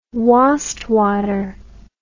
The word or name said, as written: wastwater